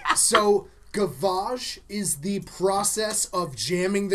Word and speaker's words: gavage: Called gavage. – Yep. Gavage. – Gavage. That's how I heard someone pronounce it on Chef's Table. – G-A-V-A-G-E. – Guavage.